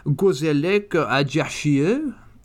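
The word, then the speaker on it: Gazélec Ajaccio: He also went on to play in France with a team called Gazelec Ajaccio. I probably haven't pronounced that very well. Do you want to... let's try that again in a more French accent. Gazelec Ajaccio. Is that better?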